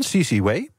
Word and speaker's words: CC Wei: Daar komt van de topman, CC Wei. Dat spreek je kennelijk zo uit.